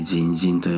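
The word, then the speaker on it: 甚深: 甚だ深いで人人と読むんですけど、深いっていうことだけだったらただ単に深いとだけ、人とだけすればいいのに、人人、甚だ深いというふうに重ねて、述べている。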